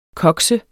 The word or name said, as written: koks